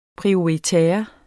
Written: prioritaire

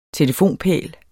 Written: telefonpæl

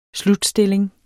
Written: SLUTSTILLING

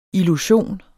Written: illusion

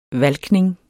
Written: valkning

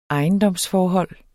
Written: ejendomsforhold

